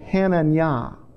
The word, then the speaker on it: Hananiah: Hananiah, or the way you would pronounce it, is Hananiah.